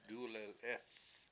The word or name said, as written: dUlelEEs